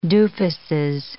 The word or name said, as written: doofuses